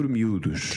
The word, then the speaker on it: miúdos: Trocar por miúdos. – To exchange for small bits or smaller, simpler words. – Yes. Okay. And so let's do the pronunciation now. Miúdos. – Accent on a U.